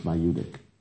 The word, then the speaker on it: maieutic: I don't remember the spelling in English, but it's pronounced Maieutic, which means midwife.